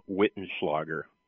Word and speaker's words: Wittenschlager: It is Tom Wittenschlager. Those German Okay. – names are always very difficult. So, but if you can say Schwarzenegger, I think you can say Wittenschlager.